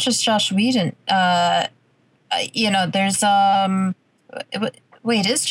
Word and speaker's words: Whedon: Whedon, yeah.